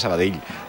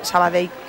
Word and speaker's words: Sabadell: El cachondo de Kiki Lesa dice, pronuncias muy bien Sabadell. Mis respetos. – A mí me lo decían mucho cuando estaba en Primera Federación, porque yo también decía Sabadell, que es como se dice.